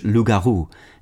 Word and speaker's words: loup-garou: English also sometimes makes use of the French-derived term loup-garou, pronounced in French loup-garou, and spelled L-O-U-P hyphen G-A-R-O-U, from the Old French le, meaning wolf, and garou or garulf, a word of Germanic origin meaning werewolf.